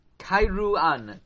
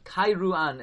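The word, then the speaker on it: Kairouan: And he was sold to the city of Karuen. Karuen, which was one of the strongest Arabic holds in the entire Africa. Kairuan. Kairuan is the way you pronounce it.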